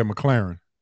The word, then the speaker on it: McClarin: Yeah, McClarin.